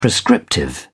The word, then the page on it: prescriptive: British English